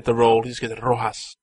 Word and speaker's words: Rojas: You gotta roll your R's. You know how to roll your R's. You know how to do it. So do it. Rojas, come on.